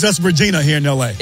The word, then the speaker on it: Regina: It's Regina. That's what? The way it's pronounced.